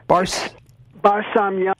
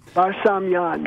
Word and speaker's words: Barsamian: How do you say your last name, David? – Barsamian. – Barsamian. Thank you. – Barsamian, yeah. – Samian. – It's Armenian.